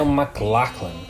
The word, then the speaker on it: MacLachlan: MacLachlan. Kyle MacLachlan. – MacLachlan.